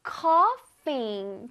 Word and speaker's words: coughing: I can't stop coughing. 좋아요. GH가 만나면 F 발음인 거 기억하세요. 자, cough까지 맞습니다.